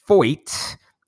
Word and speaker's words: Feucht: But while we're talking about that subject, this weekend, Sean Foyt, whose name I was told on Twitter rhymes with exploit, is holding another concert.